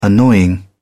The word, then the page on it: annoying: British English